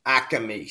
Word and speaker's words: Acme: Acme, say it right. Acme.